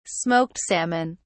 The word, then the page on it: smoked salmon: British English